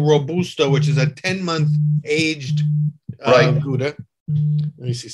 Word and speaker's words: Gouda: The Dutch would pronounce it like K-H-O-W-D-A. Gouda. – Gouda. Uh-huh. And of course, and as an American, I always have called it Gouda, but you can see, boy, a thick rind on that and a whole different characteristic.